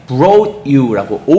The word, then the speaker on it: brought: 이거를 한국 사람들이 brought you라고 O 발음하시는데 아니에요. Brought you 발음하시죠? – Brought you 이런 식으로 발음하셔야지 brought you 오우 발음하시면 안 됩니다.